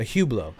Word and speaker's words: Hublot: It's like the watch. A Hublot. – Oh. – Hublot. Jean-Luc Hublot.